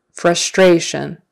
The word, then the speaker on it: frustration: Your frustration. Second syllable stress. Frustration. Frustration. Frustration.